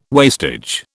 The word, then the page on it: wastage: British English